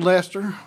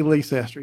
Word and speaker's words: Leicester: Irwin High School out in Leicester. I mean, you can't say Leicester. You got to say Leicester. You got to say it correctly.